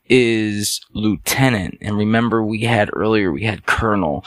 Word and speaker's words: lieutenant: In British English, the word is pronounced lieutenant, whereas in the United States, you'll hear lieutenant. You'll hear lieutenant instead of lieutenant. Okay, while both locations have kept the same spelling, you know, just to make it interesting, the U.S. pronunciation is heard increasingly often in other English-speaking countries.